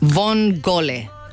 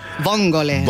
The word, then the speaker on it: vongole: Vongole. Así se pronuncia almeja en italiano. Vongole.